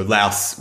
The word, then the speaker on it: Laos: I did, in my little bit of research about the pronunciation, I was kind of informed that when you were speaking about the country in the English language, the country's name should rhyme with mouse. So, Laos would be, of course, then what you're looking for when you talk about the country.